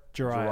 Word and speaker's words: Jireh: Jarrett Edwards, or how do you pronounce that? – Edwards. Jariah. – Jariah. – Jariah Edwards. J-I-R-E-H. Jariah Edwards.